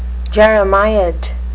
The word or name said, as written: jeremiad